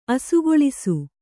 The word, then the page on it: ಅಸುಗೊಳಿಸು: asugoḷisu